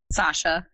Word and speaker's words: Sausha: Sausha.